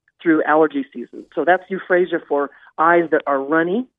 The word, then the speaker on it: euphrasia: It's spelled E-U-P-H-R-A-S, as in Sam, I-A, euphrasia.